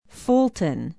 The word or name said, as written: Fulton